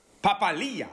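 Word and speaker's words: Papali'i: So you know what's throwing me most about the Raiders at the moment is that they've actually figured out how to pronounce some of their names. So Papali has gone to Papali. – Papalia.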